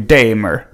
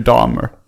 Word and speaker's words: Dahmer: Men så sa han, nej nej, han uttalade Jeffrey Dahmer.